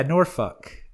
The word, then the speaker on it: Norfolk: Yeah, Norfolk. That's how you say it. That's with the accent that the people use there.